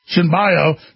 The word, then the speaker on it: Cenobio: The driver, 29-year-old Louis Cabos Sinoboi, how do you say that? Sinoboa? C-E-N-O-B-I-O, Sinbio, didn't stop, and the officer reportedly initiated a brief chase.